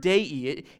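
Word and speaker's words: dei (δεῖ): It's spelled in English DEI, Delta Epsilon Iota. And it's the word dei.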